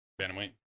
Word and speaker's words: bantamweight: I used to call it the bantamweight, but how do you pronounce it? – Bantamweight. – I've heard like bantamweight. Okay,